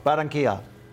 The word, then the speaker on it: Barranquilla: Barranquilla.